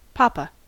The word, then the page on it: papa: American English